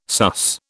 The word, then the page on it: sus: British English